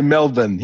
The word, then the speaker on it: Melbourne: Well, how do you say it in Australia? Melbourne? – We say Melbourne, yeah.